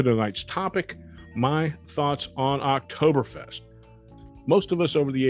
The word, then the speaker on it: Oktoberfest: The Oktoberfest, and the German pronunciation is Oktoberfest. The K is German, so you'll traditionally see it spelled O-K-T-O-B-E-R-E-F-E-S-T. The American version is Oktoberfest with a C.